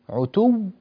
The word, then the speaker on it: عتو: في يعني ننتقل إلى الآية 22 في قوله الله تعالى: بل لجوا في عتو ونفور. عتو نعطي الواو قوة وضغطة عندما ننطق بها، وننتقل إلى الواو التي بعدها بضغطة وقوة، فهي واوان مشددتان متتاليتان، عتو و، تمام؟